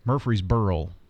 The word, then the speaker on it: Murfreesboro: Murfreesboro? – Mm-hmm. Murfreesboro. So we said it a little different. – Yeah. – The one that kills me is Murfreesboro, or there's always an L in Rutherford County.